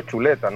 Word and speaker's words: Zuleta: Le decía, sí, Julio, Julio Zuleta se escribe, pero es, Julio Chuleta, ¿no?